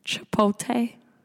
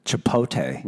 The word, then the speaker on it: Chipotle: Say it again. – Chipotle. – Chipotle. – Where's the L? – Bro, I don't fucking know. I made him watch commercials. I'd be like, see? See how they say it? This is how it's pronounced.